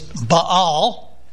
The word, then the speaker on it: Baal: It's pronounced B-A-L-E, or if you get the Hebrew pronunciation, it's Baal.